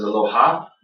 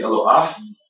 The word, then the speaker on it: Eloah: The singular form of Elohim is Eloah, and some people mispronounce this as Eloha, but it's actually Eloah.